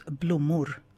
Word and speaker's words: blommor: And if you're talking about multiples, it's blommor. – Blue Moor.